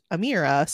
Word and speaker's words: Emira: Even Amira's name is spelled with an E. And so I'm looking at it right now and I didn't even realize that, but it's pronounced Emira.